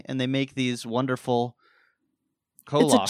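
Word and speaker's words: kolache: It's like cloche? – Kolosh. – Kolosh? – Kolosh.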